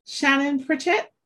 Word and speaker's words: Shannon Pritchett: I am here today with Shannon Pritchett.